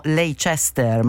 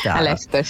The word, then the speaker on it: Leicester: La professoressa è collegata con noi, bioingegneria ambientale, università di, noi diremmo Leicester, ma non si dice così. Buongiorno professoressa. – Buongiorno. Leicester, sì, esatto.